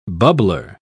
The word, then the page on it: bubbler: British English